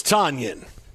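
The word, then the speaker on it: Tonyan: His name is Tanyan. Robert Tanyan. – Robert Tanyan. Say it like Robert Loggia.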